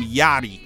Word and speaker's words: Yachty: Because I know you want me to pronounce his name Yachty. – Right. You keep calling me Yachty.